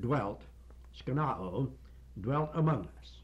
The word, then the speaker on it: skenoo: Now, the word skenao, which comes from the noun, that's the verb, skenao, I spelled S-K-E-N-O-O, the last O is the long O, it provides further meaning, further information as to the developed meaning of this word.